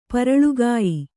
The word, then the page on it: ಪರಳುಗಾಯಿ: kannada